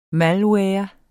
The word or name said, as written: malware